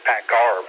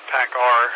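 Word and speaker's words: PACCAR: I said pack car, but I've heard it pronounced both ways. – Pirate way of saying it, pack R.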